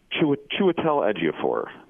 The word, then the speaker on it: Chiwetel Ejiofor: And then Chiwetel Ejiofor.